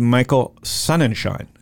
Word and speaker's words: Sonnenschein: His name is Michael Sonnenschein. And it really is pronounced that way because I know because we practiced it for 10 minutes just before with Michael.